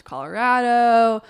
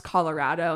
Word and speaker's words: Colorado: Some of us went to Colorado. I did also just learn recently that it's pronounced Colorado instead of Colorado. From David House Connect the Native, because he's from Colorado. – Heavy influence on the A.